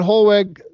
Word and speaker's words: Hollweg: In fact, they said, why do you, Bethmann Hollweg, the W is pronounced like a V, the chancellor said, why are you going to go to war over a scrap of paper?